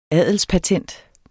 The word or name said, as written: adelspatent